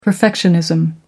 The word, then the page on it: perfectionism: American English